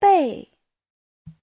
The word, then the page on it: 被: bei4